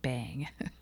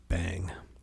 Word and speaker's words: Beyng: So I used a text-to-speech program, and the robot woman pronounced B-E-Y-N-G, bang. But the only other way I've heard it pronounced is being, which, you know, makes no sense.